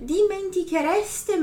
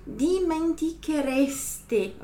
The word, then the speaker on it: dimenticherete: Voi non vi dimenticherete mai. Então, vocês nunca se esqueceriam, ok? Voi non vi dimenticherete, ok? Dimenticherete. Então, pronuncia bem esse E final.